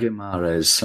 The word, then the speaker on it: Guimarães: Is it Guimarães? – I think it's Guimarães, isn't it? – Guimarães, okay.